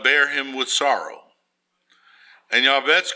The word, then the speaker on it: Jabez: We need to be like Yabetz in the Bible. The name looks like Jabez to you in the book of Chronicles, but it's pronounced Yabetz.